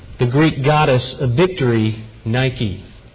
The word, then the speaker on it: nike: In fact, the noun form, nike, maybe you've heard we pronounce it the Nike, not Nike tennis shoes, but you've heard of the Nike missile.